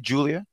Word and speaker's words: Giulia: Julia? – Yeah. – Okay. You know what it is? It's the G because, you know, used to with a J, but Julia, she spells it with a G because she's a real G.